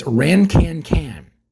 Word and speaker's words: Rinquin: and I'm going to get this right because it's pronounced differently, it's Rancancan. R-I-N-Q-U-I-N, Rancancan. Rancancan, all right?